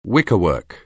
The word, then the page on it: wickerwork: anglais